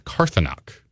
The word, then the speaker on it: Karthanok: And then on Saturday we have a Russian Imperial Stout called Karthanok is what we're pronouncing it.